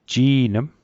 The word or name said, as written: ji'nm